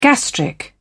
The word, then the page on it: gastric: British English